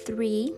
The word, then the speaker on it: three: One two, three. Lembra que o three é o TH, então three soprando.